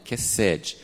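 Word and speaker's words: kesed (חסד): Eu não sei exatamente como pronuncia, mas até perguntei a uma pessoa esses dias, e eu tinha visto alguma coisa com a sílaba tônica no início, mas ele falou, não, é kesed.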